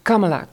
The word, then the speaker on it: Kamala: En Kamala heb ik geleerd, moet je het uitspreken. Kamala Harris is ook fantastisch. – Kamala.